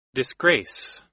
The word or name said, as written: disgrace